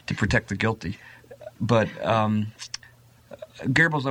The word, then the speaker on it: Goebbels: And one of the things that I discovered in my own research was that Goebbels, who did the... Is that the way it's pronounced? – Yeah, I call him by another name.